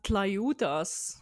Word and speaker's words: Tlayudas: Ähm, Tlayudas. Wie schreibt man Tlayudas. – T-L-A-Y-U-D-A-S.